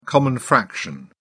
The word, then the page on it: common fraction: British English